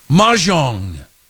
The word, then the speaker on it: Mahjong: Mahjong, as Kylie Brooks told me to pronounce that correctly.